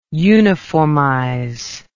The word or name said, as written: uniformize